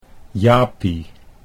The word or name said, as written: Japie